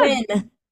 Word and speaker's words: Nguyen: I mean, it's spelled, for those who don't know, it is spelled N-G-U-Y-E-N. So you would think something completely different, but it's definitely not phonetic.